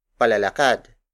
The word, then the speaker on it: palalakad: For example, palalakad, palalakad, palalakad. Palalakad is spelled as P-A-L-A-L-A-K-A-D.